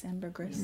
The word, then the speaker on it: ambergris: Ambergris? – No. – Ambergris? – That's how I've always heard it. – Less grease and more gris.